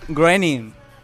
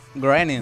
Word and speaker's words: Groening: Running. – ¡Qué internacionales estamos hoy! – Por favor. – Me encanta. – Si no sabes pronunciar, no me digas. – Es Groening. Groening.